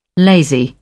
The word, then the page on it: lazy: British English